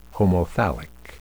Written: homothallic

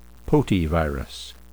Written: potyvirus

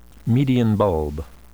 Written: median bulb

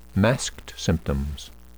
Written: masked symptoms

